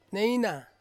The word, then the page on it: neina: Ansus